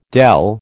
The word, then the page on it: dell: American English